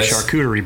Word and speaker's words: charcuterie: do love me did a I, charcuterie board though. Did I say that right? – I think you did.